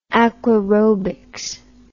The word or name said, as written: aquaerobics